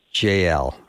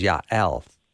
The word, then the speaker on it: Jael: Now, when I started this interview, I, I, I called her JL or Jail. so I've been corrected twice. The first one was JL, and the proper Hebrew pronunciation is Yael.